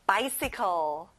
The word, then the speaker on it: bicycle: 자전거, bicycle. 앞에 by에 크게 말해볼까요? Bicycle. That's right.